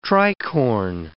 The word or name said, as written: tricorn